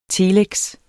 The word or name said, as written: telex